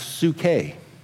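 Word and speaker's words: psuche (ψυχή): Soul in the Greek is pronounced psuche.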